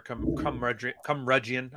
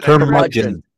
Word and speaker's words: curmudgeon: Cumrudgeon. How do you pronounce that shit? – Curmudgeon.